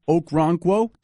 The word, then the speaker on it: Okoronkwo: The other Oklahoma player pick this year, Coach, was, and I hope I get the pronunciation correct here, Obo Okoronkwo.